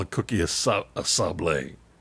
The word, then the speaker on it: sablé: Now, it's spelled S-A-B-L-E with an apostrophe over the E, so I guess it's sablé.